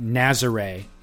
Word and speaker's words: Nazaré: Nazaré is the official pronunciation for the Big Wave spot in Portugal.